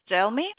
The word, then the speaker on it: Delmy: Delmy. – Spell that for me. – D-E-L-M-Y.